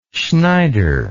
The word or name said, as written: schneider